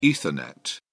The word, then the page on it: ethernet: British English